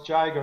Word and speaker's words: Jaeger: I had a little bit of his speech on NHL 360 tonight, and he said, no, it's Jaeger, and then he had a go at his parents for coming up with strange names for his two sisters. So Mum and Dad pronounce it with a J, not a Y.